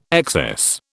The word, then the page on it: excess: British English